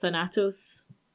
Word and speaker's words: Thanatos: The Greek personification of Death was a character called Thanatos, or probably more likely pronounced Thanatos in the Greek, which I presume is where Marvel Cinematic Universe gets Thanos from.